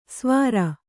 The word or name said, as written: ಸ್ವಾರ